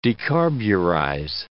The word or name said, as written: decarburize